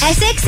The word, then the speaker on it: Essex: Essex? Am I saying that right? – Yeah.